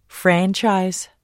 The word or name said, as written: franchise